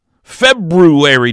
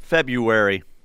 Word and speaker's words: February: February. Do you pronounce the R, Teach? – February. – So you do not pronounce the R? – No, February. – You intentionally skip the R?